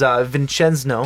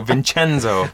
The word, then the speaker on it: Vincenzo: His name is Vincenzo. No, nope, I said it wrong. – I even told you how to say the name before the show. Vincenzo.